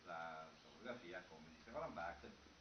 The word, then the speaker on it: Barthes: Ha scritto Barthes, non so. Sì. Però si legge Barthes.